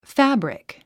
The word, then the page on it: fabric: fǽbrik; ファブリク